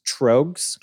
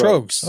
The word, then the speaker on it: Tröegs: Is it Tröegs then? – Tröegs. Pronounced. There's a thing. I can't play it on air. – On this episode, rhymes with rogues. – Rogues. So Tröegs.